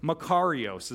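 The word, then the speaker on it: Makarios (μακάριος): It does mean blessed, but it's the Greek word makorii or makorios. Everybody say that, makario. I said that wrong. I had to like look up how to pronounce it this morning. Makarios is how you say it. Everybody say that out loud, makarios.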